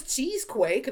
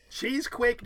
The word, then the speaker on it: Cheesequake: Cheesequake, New Jersey is not Cheesequake. It's Cheesequake. – Cheesequake, two syllables.